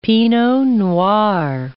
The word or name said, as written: pinot noir